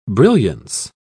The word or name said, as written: brilliance